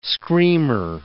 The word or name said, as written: screamer